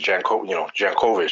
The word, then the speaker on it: Jankowicz: And you said it right. It's Jankowicz. It's not Jankowicz. It's Jankowicz, just how she said it.